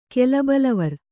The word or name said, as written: ಕೆಲಬಲವರ್